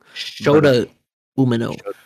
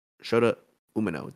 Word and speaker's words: Shota Umino: So the first match of the night was actually Jericho, Guevara, and Suzuki versus Kingston, Yuta, and Umino. I think I might be saying his name wrong. – Shota Umino. – Shota Umino.